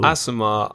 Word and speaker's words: Aasimar: So Aasimar... – Did I say that right? – Yeah. Cool. Aasimar are humans who have essentially got a celestial blood lineage and thus are born with innate celestial properties.